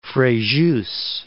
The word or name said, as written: Frejus